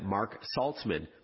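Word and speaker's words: Marc Saltzman: If you want to say hi on social media, I'm at Mark Saltzman on Twitter, Instagram, LinkedIn, Facebook, or YouTube. Mark is spelled M-A-R-C.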